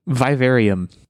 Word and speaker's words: Vivarium: A movie called, and I'm hoping I'm pronouncing this correctly, Vivarium. V-I-V-A-R-I-U-M. Like aquarium, but with Viva at the front.